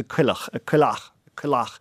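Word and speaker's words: coileach: So the Irish for a cockerel is a cuilleach in Ker, in my Gaeltacht. You'd stress the second syllable.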